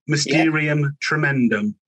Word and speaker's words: Mysterium Tremendum: And the album called Mysterium Tremendum. Is that, have I pronounced that right, Captain? – That's right. Mysterium Tremendum.